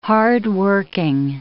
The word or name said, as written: hardworking